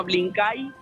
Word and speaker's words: Blinkay: Se llama Blink la aplicación con K, ¿no? Blink Igual si traes el código QR te lo baja directamente. – Sí, se llama Blinkay se escribe Blinkay Belarga L-I-N-K-A-Y ahí te bajas la app.